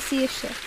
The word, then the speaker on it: Saoirse: They looked like Sarah Rhodes, but it was actually Saoirse.